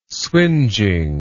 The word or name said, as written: swingeing